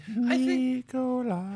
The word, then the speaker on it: Nikola: Nikola. – That's how you pronounce it, not Nikola, by Nikola.